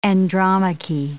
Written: Andromache

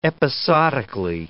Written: episodically

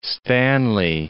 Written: Owen Stanley Range

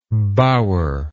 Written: bowerlike

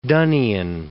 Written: Donnian

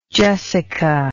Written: jessica

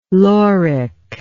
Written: lauric

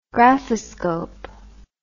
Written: graphoscope

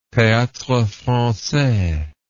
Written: theatrefrancais

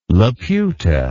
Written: Laputa

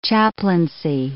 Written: chaplaincy

